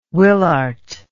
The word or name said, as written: Willaert